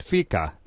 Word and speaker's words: fika: What is this, and are we saying it right? Fika, F-I-K-A. – Yeah. Yeah, you've got your Swedish down pat.